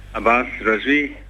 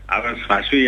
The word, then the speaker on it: Abbas Razvi: Abbas Razvi. Abbas Razvi er der mange, der udtaler mit navn som sådan.